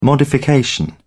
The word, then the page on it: modification: British English